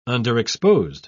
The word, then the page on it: underexposed: British English